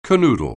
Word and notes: canoodle: anglais

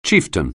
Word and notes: chieftain: English

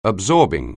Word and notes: absorbing: anglais

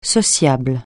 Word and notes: sociable: francais

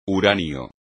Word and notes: uranio: espagnol

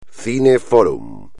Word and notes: cine fórum: espagnol

